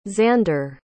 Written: xander

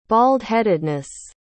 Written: baldheadedness

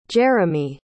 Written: jeramy

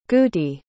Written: gootee

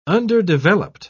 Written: underdeveloped

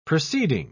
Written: proceeding

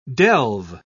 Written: delve

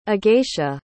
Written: agacia

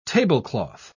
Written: tablecloth

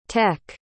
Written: tek

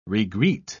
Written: regreet

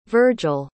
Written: virgil

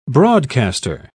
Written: broadcaster

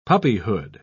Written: puppyhood

